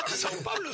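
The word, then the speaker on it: São Paulo: ¿Sao Paulo o Sao Paulo? – No, Pablo.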